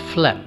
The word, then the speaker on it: phlegm: Phlegm. How do you think it is pronounced? It's pronounced as phlegm.